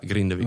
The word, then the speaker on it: Grindavík: Dus Grindavík, hè? Spreek je het ook zo uit? – Ja, Grindavík.